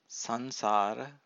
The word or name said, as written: sansara